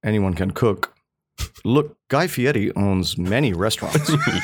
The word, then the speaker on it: Fieri: Fieri, I believe. Don't look at how it's spelt. – Right. – Look only at how it feels to say.